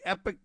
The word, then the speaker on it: Epoch: The Epoch Times sometimes is pronounced The Epoch Times.